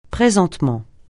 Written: présentement

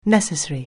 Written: necessary

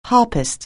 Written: harpist

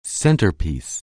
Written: centerpiece

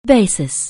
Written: basin

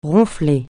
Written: ronfler

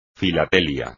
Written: filatelia

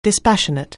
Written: dispassionate

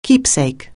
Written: keepsake

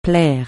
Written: plaire

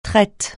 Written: traite

